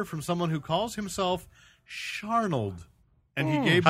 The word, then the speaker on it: Searnold: S E A R N O L D, and then he spelled it out phonetically for me. Sharnold.